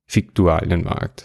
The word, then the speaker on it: Viktualienmarkt: Schreibt man eigentlich mit V, aber man sagt unbedingt Fiktualienmarkt. So heißt's. Und dann bin ich auf den äh, Fiktualienmarkt gegangen und habe geguckt,